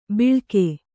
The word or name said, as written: ಬಿಳ್ಕೆ